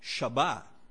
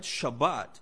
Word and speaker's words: Shabbat: the Hebrew root for the word Sabbath is would be transliterated as S-B-T, but it's pronounced Shabbat, right? Shabbat.